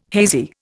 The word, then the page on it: hazy: Indian